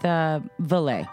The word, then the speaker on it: Valle: Now the savagery came to light when Angela Valley, that's the one, Valley. I mean, is that how would you pronounce that? V-A-L-L-E, Valley. – I'm gonna go with Valley.